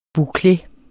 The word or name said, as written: boucle